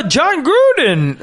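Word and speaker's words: Jon Gruden: He goes, John Gruden.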